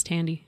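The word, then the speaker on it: Thandie: I think it's Tandy. – Is it Tandy? – Mm-hmm. – Like the computer? – I'm not really sure how she pronounces the Radio Shack computer.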